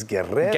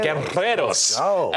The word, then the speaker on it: Guerreros: I grew up in a Cuban household, I had to roll your R's for you. – Yes, thank you. Yes. Alexis Guerreros. – Guerreros.